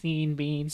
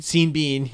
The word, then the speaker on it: Sean Bean: I believe it's pronounced Sean Bean. – Sean Bean. My mistake.